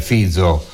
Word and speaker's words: Fico: Eccolo qui il messaggio è arrivato, è di Daniele da Pisa, che però ha vissuto in Slovacchia e mi corregge giustamente, dice che è Fico il cognome, si pronuncia Fizo, Quindi mi correggo, ringrazio naturalmente Daniele.